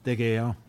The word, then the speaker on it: De Gea: Men jeg sier De Gea, hvis den uttalen av det spanske navnet holder. – Veldig bra. Veldig bra.